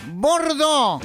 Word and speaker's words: Bordeaux: Bordó.